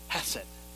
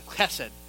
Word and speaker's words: hesed: There's a word in Hebrew called hesed. I know I didn't say that right, but it's hesed.